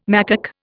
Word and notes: macaque: Indian

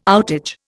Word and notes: outage: Indian

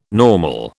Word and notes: normal: British English

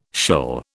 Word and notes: shill: British English

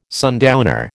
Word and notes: sundowner: American English

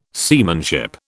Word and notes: seamanship: British English

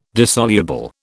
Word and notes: dissoluble: British English

